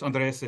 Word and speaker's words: Andres: Yeah, it's Spanish. Andres, if you don't speak Spanish. – Andres.